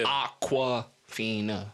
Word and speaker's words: Awkwafina: Like, it's spelled like. – How it's pronounced. – Yeah. – Aqua. Fina.